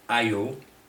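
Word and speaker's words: adjö: One is Old Swedish word, pronounced ayu, which is used mainly in old literature, books, and speaking times.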